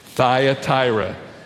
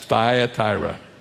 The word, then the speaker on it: Thyatira: Say the word out loud with me, Thyatira. You won't believe how that word is pronounced by some folks, but that's the way it's pronounced, Thyatira.